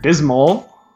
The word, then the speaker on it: Pepto-Bismol: Pepto-Bismol? Is that what you just said? – Is it Bismol? – Yeah. – I've been saying it wrong my whole life.